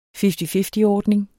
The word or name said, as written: fifty-fifty-ordning